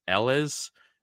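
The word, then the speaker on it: Eeles: is I think it's Ellis. It's E-E-L-E-S. That double E always throws me off.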